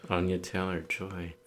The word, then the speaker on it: Anya Taylor-Joy: Anya Taylor-Joy is playing.